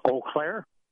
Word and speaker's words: Eau Claire: Can you pronounce the name of your city so I don't get it wrong? – Eau Claire. – Okay, see, I would have gotten it wrong.